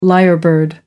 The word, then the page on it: lyrebird: American English